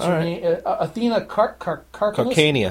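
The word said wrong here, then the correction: Karkanis: Carcanus. – Carcanus.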